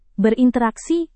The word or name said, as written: berinteraksi